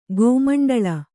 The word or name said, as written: ಗೋಮಂಡಳ